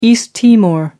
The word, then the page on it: east timor: American English